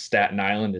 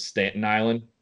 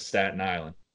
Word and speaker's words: Staten Island: And the Yankees just released a commercial of him where he mispronounced Staten Island as Stanton Island for 30 seconds.